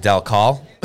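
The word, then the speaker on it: Dal Colle: Delcol, the E's, I think, was correcting our pronunciation.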